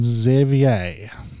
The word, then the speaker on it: Xavier: Shekhar, Shelby, Simon, Stephanie, Summer, Tara, Victoria, Vincent, and Xavier. – I was like, what are you doing? – I'm pronouncing it the French way.